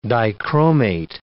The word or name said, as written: dichromate